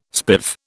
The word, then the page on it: spiv: British English